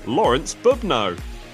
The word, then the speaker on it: Lőrinc Bubnó: And this week, even though his name doesn't look like it's pronounced like this, Lőrinc, Bubnó.